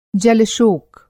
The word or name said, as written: जलशोक